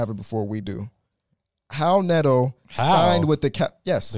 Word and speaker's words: Raul Neto: Do you not know that's how you pronounce his name? – I didn't. I thought it was Raul Neto. – Hal Neto. H is Hal Neto by the pronunciation.